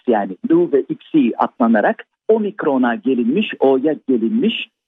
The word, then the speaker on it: Xi: X-I harfleri de Xi diye okunuyor. O da biliyorsunuz Çin Devlet Başkanı'nın adı.